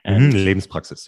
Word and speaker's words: Lebenspraxis: I don't know if I pronounce this correctly, but Lebenspraxis. – Lebenspraxis, yeah.